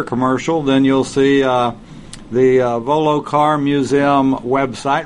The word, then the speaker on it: Volo: Is Volo the right way to say it up in Illinois? – Yep, Volo Auto Museum.